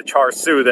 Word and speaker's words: char siu: So I'm going to say it incorrectly the entire time, but I did look it up after the fact, and it's the char siu that I got, not what I said.